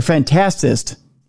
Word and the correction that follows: fantasist: Fantasist. Yes. Urban fantasist, yes. – I put the wrong emphasis on the wrong syllable there, Charles.